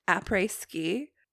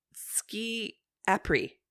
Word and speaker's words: après-ski: Do you think Taylor knows it's pronounced après-ski? Because she said ski apri.